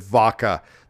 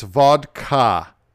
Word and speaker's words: vodka: He said vodka. That drives me fucking crazy when people do that. Come on. You can pronounce the D. It's vodka. Okay. – Yes. – Say it with me now.